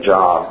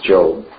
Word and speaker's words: Job: 1st and 2nd Kings, 1st and 2nd Chronicles, Ezra, Nehemiah, Esther, Job. Now again, some of these look like, it looks like Esther, but it's Esther, and it looks like Job, but it's Job.